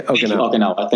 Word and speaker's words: Okinawa: like Okawana in Japan, if I said that wrong. And then there's a place Okinawa. – In California. – Okinawa.